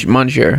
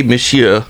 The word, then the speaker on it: monsieur: I think it's monsieur. – No, it's pronounced monsieur.